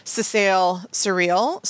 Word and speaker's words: Secale cereale: So it's often called, and I'm going to mispronounce it here, sesale cereale. So S-E-C-A-L-E, C-E-R-E-A-L-E, or it would often be in parens like rye seed extract.